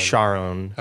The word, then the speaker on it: Charon: So he says that you pronounce it like Char, as you were going to say Charlene, and then own. – Charon. – So Charon.